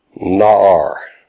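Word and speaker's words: Na'ar (נַעַר): na'ar. Na'ar, N-A-A-R, pronounced like N-A-H, na' and an R.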